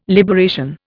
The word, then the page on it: liberation: Indian